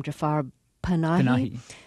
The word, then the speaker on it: Jafar Panahi: And there's an Iranian filmmaker, correct my pronunciation if it's wrong here, named Jafar Panahi who yes.